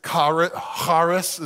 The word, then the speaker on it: charis: The Greek word is charis, is the way you'd pronounce it, but it means grace.